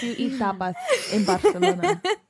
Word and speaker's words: Barcelona: because like I've never been to Barcelona. It sounds romantic as hell. – I've heard it's the most fun, most romantic city in the whole world. – It's pronounced Barcelona. Barcelona.